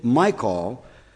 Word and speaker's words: Michal: As the ark of the Lord came into the city of David, Michal, I'm not exactly sure how that's supposed to be pronounced, but it is a long I.